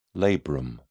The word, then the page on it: labrum: British English